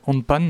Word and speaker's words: handpan: On a de l'aquarelle, du handpan, alors j'espère que je le prononce bien.